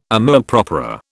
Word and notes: amour propre: British English

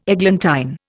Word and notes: eglantine: Indian

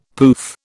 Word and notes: pouf: British English

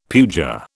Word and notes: puja: British English

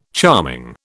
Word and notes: charming: British English

